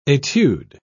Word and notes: etude: British English

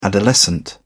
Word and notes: adolescent: British English

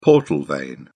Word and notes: portal vein: British English

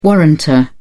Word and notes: warrantor: British English

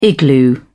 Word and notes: igloo: British English

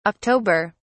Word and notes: october: British English

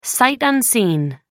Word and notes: sight unseen: American English

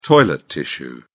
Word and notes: toilet tissue: British English

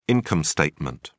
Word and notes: income statement: British English

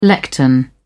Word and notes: lectern: British English

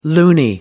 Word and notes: loony: American English

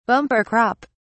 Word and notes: bumper crop: British English

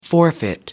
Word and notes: forfeit: American English

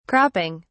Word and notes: cropping: British English

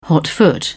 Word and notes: hotfoot: British English

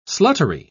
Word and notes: sluttery: British English

